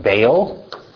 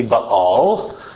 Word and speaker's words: Baal: So Jezebel, when she marries Ahab, she imports with her the Phoenician worship of, and I'm going to pronounce it the Hebrew way, though you're probably used to hearing it Baal. Okay, it's technically Baal, and since we are in a Hebrew class, we're going to go with Baal, specifically Baal Melkart.